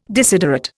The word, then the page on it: desiderate: Indian